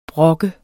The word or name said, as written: brok